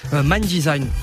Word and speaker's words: MNDSGN: Mind Design. Okay. Mind Design s'écrit M-N-D-S-G-N.